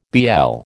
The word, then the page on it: BL: American English